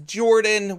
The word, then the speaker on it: Jorphdan: My name is Jorphdan with a silent PH in the middle, and I am joined always by my wonderful co-host Sir Lucien over at Sir Lucien Gaming.